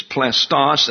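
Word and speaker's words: plastos (πλαστός): It's plastos, an accent on the final syllable. That's P-L-A-S-T-O-S. plastos.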